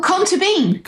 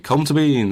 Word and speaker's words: Colm Tóibín: And this year, the Booker Prize shortlisted was The Testament of Mary, which is written by Colm Tóibín. Is that right? – Oh, I don't know. – You know this. You know how we pronounce this. We talked about this weeks ago. – Oh, Colm Tóibín. – Colm Tóibín. There we go.